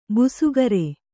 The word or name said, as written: ಭಾಸ್ಕರಿ